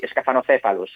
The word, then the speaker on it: Scaphanocephalus: Ara, si ho dic bé, el nom científic d'aquest organisme seria Scaphanocephalus? – Sí, sí, és mal de pronunciar, però sí, Scaphanocephalus, sí.